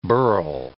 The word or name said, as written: burl